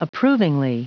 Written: approvingly

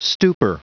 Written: stupor

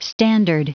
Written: standard

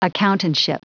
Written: accountantship

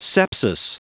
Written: sepsis

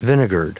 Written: vinegared

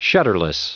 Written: shutterless

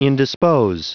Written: indispose

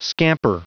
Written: scamper

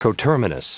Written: coterminous

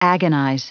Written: agonize

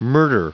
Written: murder

